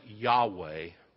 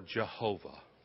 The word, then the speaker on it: Yahweh: It consists of four consonants that in English are YHWH. We pronounce it Yahweh or Jehovah.